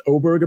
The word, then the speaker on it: Åberg: It's pronounced Oberg, apparently, which is interesting.